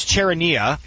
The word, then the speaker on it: Charania: You remember when AK you know, Shams, Charaneria? Oh, how do you say his name? – Charaneria? – Shams Charania.